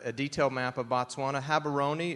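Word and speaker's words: Gaborone: Um, you may hear some white people in South Africa say, say Gabaroni, um, or here in South Carolina, but it's Habaroni, um, sort of a silent G.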